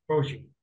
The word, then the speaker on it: Bochy: Bochy. – Bochy.